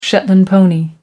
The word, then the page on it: shetland pony: American English